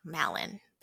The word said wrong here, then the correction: Malon: That is a very interesting way to pronounce her name. You got it. Double points because you got the game. It is Malon from Ocarina of Time.